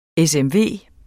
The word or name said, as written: SMV